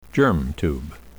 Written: germ tube

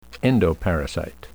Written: endoparasite

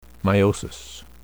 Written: meiosis

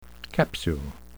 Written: capsule

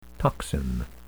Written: toxin

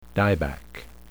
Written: dieback

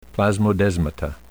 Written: plasmodesmata

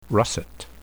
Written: russet

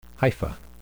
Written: hypha

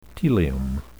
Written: telium

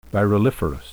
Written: viruliferous

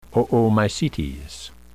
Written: Oomycetes

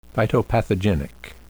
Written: phytopathogenic

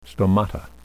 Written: stomata

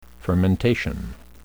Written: fermentation

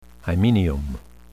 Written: hymenium

